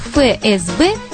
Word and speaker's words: ФСБ: Если это буквенная аббревиатура, то надо произносить по названию букв ФСБ. Но так не говорят. Почему? Название буквы «Ф» в разговорной речи произносится как «Ф», и это исключительное соображение экономии. Экономии речевых средств, наших с вами артикуляционных физических усилий. Поэтому сегодня, в 2003 году, Произношение ФСБ считается нормативным, так же как и ФРГ, ФБР.